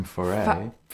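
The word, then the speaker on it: Fauré: From Farah. – From Foray. – Foray, sorry. My pronunciation is terrible.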